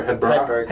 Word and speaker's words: Hedberg: Who, like, I'm not even, like, is it Hedberg or Hedberg? – Hedberg.